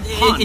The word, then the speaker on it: Honne: H-O-N-N-E. – Oh, yeah. – Hon. Well, Hon. In Japanese, it's Honne. – Oh, yeah. – Honne. – Yeah. – But they pronounce it Hon, so it's weird.